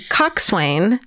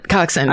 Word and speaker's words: coxswain: So, I was on the crew team, those boats with 8 rowers and a tiny coxswain. And she wrote out how to pronounce it. Coxswain. – Coxswain. – I said it wrong. Coxswain. – It's spelled coxswain. – It is spelled coxswain.